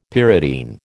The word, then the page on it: pyridine: American English